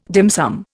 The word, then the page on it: dim sum: Indian